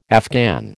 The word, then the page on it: Afghan: American English